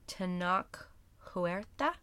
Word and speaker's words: Tenoch Huerta: played by Tenoch Huerta.